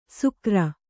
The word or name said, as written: ಸುಕರ